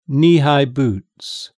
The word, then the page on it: kneehigh boots: British English